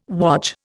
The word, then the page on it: wodge: Indian